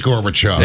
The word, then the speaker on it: Gorbachev: Is it Gorbachev? – It is Gorbachev.